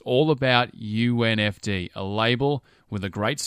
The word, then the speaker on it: UNFD: And yes, it's pronounced as an initialism, UNFD, not unified.